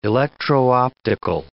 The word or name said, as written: electro-optical